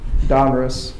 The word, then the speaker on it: Donruss: So we have Don Ross. Donruss. I always pronounce it Ross.